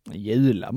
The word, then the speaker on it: Jula: Är det Jula eller är det Jula? – Jula måste det vara. – Ja, jag säger också Jula.